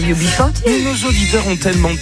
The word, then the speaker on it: UB40: UB40 ou je sais pas comment on prononce en anglais. – UB40. – Mais c'est... – Ah mais c'est ça, c'est UB40, c'est ça? – Ah oui.